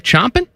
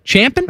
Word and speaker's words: champing: Chomping? Champing? – I think it's champing, but chomping could be acceptable too. – Here it's spelled champing, but I've never used that. Chomping at the bit. – Because it's like what a horse does. He champs on it.